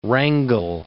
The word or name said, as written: Wrangel